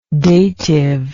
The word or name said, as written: datival